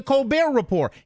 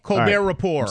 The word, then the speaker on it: Colbert Report: It's The Colbert Report. He doesn't have he doesn't pronounce the T on the end of his name, nor does he pronounce the T at the end of report.